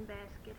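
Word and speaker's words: Baskett: Helen Basket Hester, H-E-L-E-N, B-A-S-K-E-T-T, H-E-S-T-E-R.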